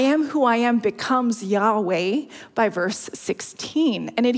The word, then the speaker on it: Yahweh: And the true pronunciation of the name has been a mystery for centuries. So let that sink in. Though more recent scholarship does suggest Yahweh is correct.